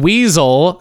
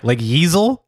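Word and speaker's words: Weasyl: Furry, and Weasel, spelled with a Y, W-E-A-S-Y-L. – You said which, Weasel spelled with a Y, and I was like Yeezel? – Which I'm talking about.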